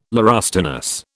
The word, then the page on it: laurustinus: British English